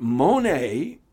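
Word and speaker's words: mone (μονή): Because the Greek word that is translated mansions is the word mone, and that's spelled M-O-N-E, pronounced a little bit differently, mone.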